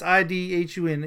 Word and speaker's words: Idhun: It's I D H U N Idhun.